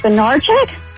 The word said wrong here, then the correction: Bednarik: Chuck Bednarik.